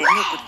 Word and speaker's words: MOOC: MOOC と書いてムークと読むんだと思うん